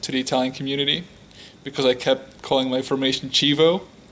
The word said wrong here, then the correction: Chievo: where it's actually Chievo, I think. – Yes, right? – Chievo? That's the pronunciation.